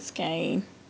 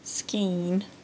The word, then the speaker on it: skein: I always said skein, but apparently that's not how you pronounce it. It's supposed to be pronounced skein, and I don't like to say it that way, so I just will say ball or skein.